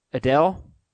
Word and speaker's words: Adel: This email is from, how would you pronounce this name? A-D-E-L. Adele? – Adele? – Adel. – Adel? Okay. Dear, hey.